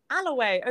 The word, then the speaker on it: Alloway: Yes, good. Not Holloway. – I can say it. I just like to stir you up with Holloway. – No, it's because I'm triggered because my entire life people have got my last name wrong and it's always like, Alloway. No. Is it Holloway? No. Is it Alloway? No, it's just Alloway.